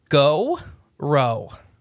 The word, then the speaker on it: Gowrow: Now, it's spelled G-O-W-R-O-W. So when you say it, you got to say it like with...